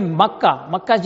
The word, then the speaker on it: مکہ: کیونکہ مکہ یہ زبر جو ہے میم کے زبر، کاف کے